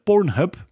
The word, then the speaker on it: Pornhub: Jeroen, eh, de mij totaal onbekende website Pornhub.